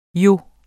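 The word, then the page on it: jo: Danish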